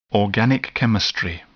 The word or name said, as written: organic chemistry